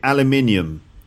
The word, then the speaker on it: aluminium: And that's because between American English and British English, they pronounce the words differently. So in Canada or in America, they'd say aluminum. And in Britain, we'd say aluminium.